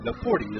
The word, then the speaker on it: Nieporte: Quincy Napoorty, last name is N-I-E-P-O-R-T-E. Napoorty is how it's pronounced, and he has joined the ball club.